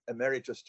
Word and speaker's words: emeritus: Well, it's usually pronounced emeritus, but basically, when you retire as a professor, you can be awarded the emeritus title,